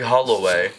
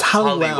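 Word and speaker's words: Halliwell: Are we saying her name wrong? – Jerry Holloway? Holloway. – No. Jerry Hallowell. Hollywell. Holloway. Isn't the W-E-L-L? H-A-L-I-W-E-L-L. – Oh. Holloway. – It's Hollywell. Hollywell. And you're all saying Holloway, like, is that a golf brand? – Who's Holloway?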